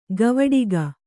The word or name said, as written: ಕವಡಿಕ